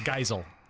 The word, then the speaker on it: Geisel: Geisel.